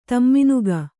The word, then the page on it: ತಮ್ಮಿನುಗ: tamminuga